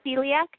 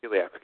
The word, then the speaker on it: Celiac: I actually do not have, how do you pronounce it again? I've always been confused with that. – Celiac disease? – Celiac, okay.